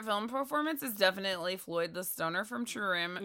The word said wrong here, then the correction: True Romance: Your pronunciation of True Romance is not iconic.